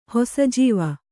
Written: ಹೊಸಜೀವ

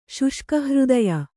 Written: ಶುಷ್ಕಹೃದಯ

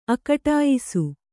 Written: ಅಕಟಾಯಿಸು

